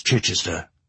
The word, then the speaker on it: Chichester: "Look," he said, "all you have to do to pronounce Chichester is soften the vowels and swallow more than you say.